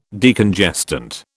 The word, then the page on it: Decongestant: British English